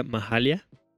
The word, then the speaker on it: Mahalia: Esto que acabamos de escuchar es nada más y nada menos que una artista muy especial llamada Mahalia. O no sé muy bien cómo se pronuncia. – Sí, yo creo que la pronunciación es correcta, Mahalia.